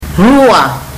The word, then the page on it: rua: fiji